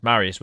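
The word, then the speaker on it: Mariusz: And it's Marius Boron. It's Marius with a Z on the end. I wouldn't have thought to do that, but that's just one of many examples of my ignorance.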